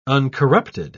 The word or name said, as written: uncorrupted